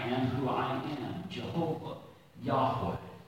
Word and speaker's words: Yahweh: Now, our parents learned this name as Jehovah. We now are taught to pronounce it Yahweh.